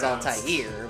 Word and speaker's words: Altair: It should be, but the correct pronunciation is Altair, but...